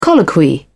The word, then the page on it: colloquy: British English